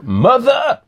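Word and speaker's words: mother!: You know, I was very fortunate to be at the premiere, the French premiere of Mother. Yeah. I have to say it like that because there's an exclamation mark in the title.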